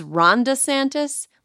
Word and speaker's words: Ron DeSantis: First, Florida Governor Ron DeSantis, or in my head, I use the way that, like, Donald Trump pronounces it, which is Ron DeSantis, like the name Rhonda.